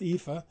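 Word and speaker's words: EAFE: That's how it's pronounced, EFA.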